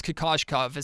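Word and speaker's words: Kokoškov: I think it's Kokoschkov is how you pronounce the last name.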